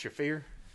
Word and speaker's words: Shafir: I think it's pronounced Shafir? – Yes.